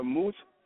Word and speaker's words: Muse: Hey, we're going to be doing this kickboxing title fight coming up this Friday at the Moose Youth Center. It's spelled M-U-S-E, but it's pronounced like M-U-Z-E.